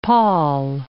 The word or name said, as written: pol